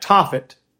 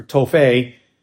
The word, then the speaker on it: Tophet: Historically, that Tophet, or Tophet, depending on how you want to pronounce it, is a place in the Valley of Hinnom where human sacrifices were offered.